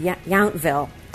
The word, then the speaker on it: Yountville: Yeah, I think that's how you say it, Yountville.